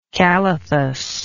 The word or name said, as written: calathus